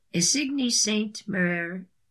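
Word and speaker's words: Isigny Sainte-Mère: Isigny Saint Marie and Fabrique Delice black truffle butter. Isigny. Saint, S-T-E, period, hyphen, mer, M-E-R-E, X, F-A-B-R-I-Q-U-E, delicious, D-E-L-I-C-E-S, black truffle butter.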